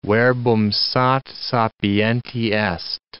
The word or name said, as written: verbum sat sapienti est